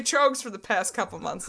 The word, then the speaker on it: Troegs: And not much pairs as well with this episode as the unseasonable Master of Pumpkins by Trogues Brewing. – Oh my God, you said it right.